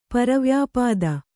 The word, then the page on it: ಪರವ್ಯಾಪಾದ: Kannada